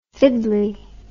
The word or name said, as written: fiddley